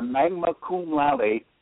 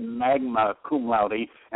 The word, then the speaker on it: magna cum laude: Would you agree that a magna cum laude would be a hot commodity to have? – Sure. – Oh, I mispronounced that. I said magna cum laude, and it's magna cum laude.